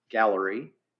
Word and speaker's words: Galleri: There are many that are emerging, and it is pronounced Galleri.